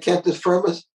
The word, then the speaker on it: cantus firmus: Is it cantus firm? – Yeah. – Is that the right pronunciation of it? – Yeah. I was just reading about it today, actually. – C-A-N-T-U-S-F-I-R-M-U-S. Yeah. Cantus firmus and cantus firmus.